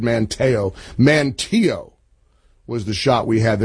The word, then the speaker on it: Manteo: Incidentally, I knew I was mispronouncing that. As soon as I said Manteo, it's Manteo.